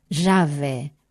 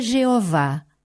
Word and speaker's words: Javé: Javé. Nunca é pronunciado, pois a identidade do Altíssimo é inexprimível. É mal pronunciado pelos cristãos, como tu fizeste, mas o nome não é Jeová.